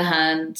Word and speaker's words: hun: My teachers call it the Hun, so that's how I'm going to say it. I'm sure I'm being laughed at, or, you know, somebody's rolling their eyes, but it is spelled H-U-N.